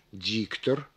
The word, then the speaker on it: диктор: Ну, например, произнося слово «диктор», мы выделяем первый слог некоторым усилением, замедлением и понижением голоса.